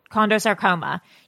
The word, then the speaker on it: chondrosarcoma: So she has disclosed now that she has a rare form of bone cancer called, and excuse me if I mispronounce it, chondrosarcoma. – Sounds right. – Chondrosarcoma.